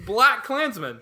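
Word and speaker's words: BlacKkKlansman: It's not Sterling K. Brown. It's Black Klansman. – He turns to the camera and he goes, K is silent.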